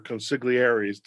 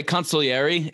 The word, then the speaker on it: consigliere: You know, I'm curious, as you're inviting your collaborators, your consigliaries, I've heard it pronounced that way. Consigliere is how they do it in The Godfather when they talk about Tom Hagen.